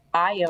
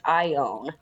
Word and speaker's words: Ione: So we are in the city of, I had to look up how to say this, Ione. Uh-huh. It's Ione, not Ion.